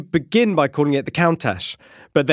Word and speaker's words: Countach: And that, I am now reliably informed, is the correct pronunciation, Countach. It's got to be quite a hard tach sound, apparently, at the end.